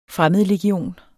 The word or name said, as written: fremmedlegion